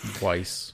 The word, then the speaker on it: Weiss: Or is it Weiss or Wise? I've heard a lot of people say Wise. – That's wise. I'm not saying Wise. Yeah. It's not spelled like Wise. It's a it's a it's a valley thing. So Weiss.